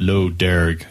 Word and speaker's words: Lough Derg: So the actual spelling of the island is Lough Derg, even though it's pronounced Lough Derg.